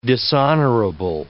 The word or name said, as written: dishonorable